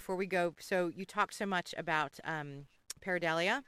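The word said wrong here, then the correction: pareidolia: Paradolia. I'm the only one that can pronounce it.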